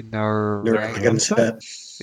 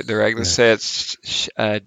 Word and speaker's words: Narragansett: N-A-R-R-A-G-A-N-S-E-T-T. – Neuragnoset. – Yeah, Neuragnoset.